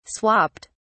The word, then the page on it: swapped: British English